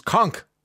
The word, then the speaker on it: conch: Sorry to correct you, but the conch shell is pronounced conch. I know this from visiting the Bahamas on many occasions where the locals sell them on street corners shouting, "Gimme dollar for conch."